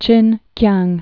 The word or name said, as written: Chinkiang